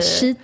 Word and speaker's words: cheat: Cheat, alltså, CH, jag hörde SH av någon anledning.